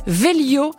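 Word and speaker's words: Vhélio: Il s'appelle Velio, ça s'écrit avec un H au milieu, V-H-E-L-I-O, contraction de vélo et de Hélios, le dieu grec du soleil.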